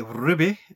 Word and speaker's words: RWBY: You might have heard me say Ruby earlier on. That's because it's spelled R-W-B-Y.